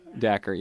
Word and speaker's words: Dacher: Apparently, that's how you say his name. Dacher.